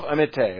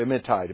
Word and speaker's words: Amittai: So he is Jonah, the son of Amittai, or Amittai, depends on how you want to pronounce that.